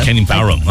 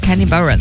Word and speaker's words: Kenny Barron: Kenny Barron 啊，Kenny Barron。